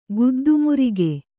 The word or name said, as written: ಗುದ್ದುಮುರಿಗೆ